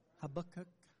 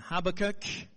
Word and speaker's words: Habakkuk: Is that how Americans say it? Habakkuk. Yeah, Habakkuk.